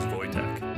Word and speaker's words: Wojtek: It's spelled Wojtek, but I was told that the pronunciation is Wojtek.